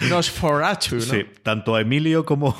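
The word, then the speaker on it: NOS4A2: Es Nosferatu, porque vamos a pronunciar a Nosferatu, aunque se escriba NOS 4A2,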